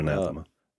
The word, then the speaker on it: anathema: Anathematha, or how do you say that? – Yeah, that's it, anathema.